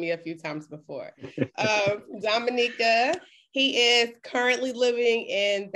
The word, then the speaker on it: Dominica: Of Dominica.